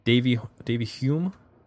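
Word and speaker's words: Davyhulme: Stephen Patrick Morrissey was born on May 22nd, 1959 in, I'm going to screw this up, Davyholme, Lancashire, England. I don't know how to pronounce Davy Hume. – Hume.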